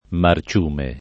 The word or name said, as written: marciume